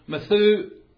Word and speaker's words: Methusael: Mahujael produced a son called Methusael. Three syllables. First syllable means to kill, to put to death or to slay. Second syllable, who or that. Third syllable, God, El.